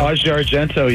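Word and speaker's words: Asia Argento: So tell us about your exclusive with Aja. Is that how you pronounce her name? – Aja Argento. – Yeah, Aja Argento.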